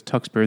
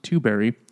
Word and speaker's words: Tuxbury: Yeah, Roxanne Tuxbury, it looks like it says Tuxbury, and this is pronounced Tuberry, who ran a motorcycle repair shop and was undismayed by the indelible stench of cat in a man's chest hair.